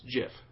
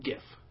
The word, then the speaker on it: GIF: Okay, now apparently the guy who came up with the file type, he says it's JIF. Okay. – Okay. – It's supposed to be pronounced JIF. That's what he says.